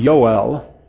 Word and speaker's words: Yoel: The name Joel, actually Hebrew technically does not have a J sound. So normally in Hebrew you would pronounce his name Yoel.